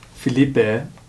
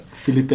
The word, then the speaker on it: Felipe: So it's Felipe is the actual, like, pronunciation.